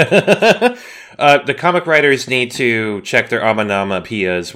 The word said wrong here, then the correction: onomatopoeia: One more time? – No. Fuck you. – Onomatopoeia. – Thank you. – Onomatopoeia.